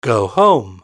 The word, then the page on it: go home: British English